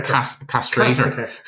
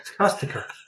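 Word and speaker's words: De Caestecker: Castaker. – Castrator. – Castaker.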